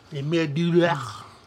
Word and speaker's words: Le Manoir du Diable: But, like, the first credited horror film is, and I'm probably going to butcher this, is Le Manoir du Diable. – Le Manoir du Diable.